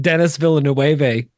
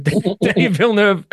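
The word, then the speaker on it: Denis Villeneuve: One of them has been that Jamie spends the first 10 episodes saying Dennis Villeneuve instead of Danny Villeneuve, because just didn't know how to say it.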